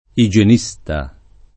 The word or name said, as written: igienista